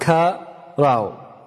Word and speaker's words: qara: And it is spelled in English the way it's spelled in this book, Q-A-R-A, and it is pronounced ka-ra-u.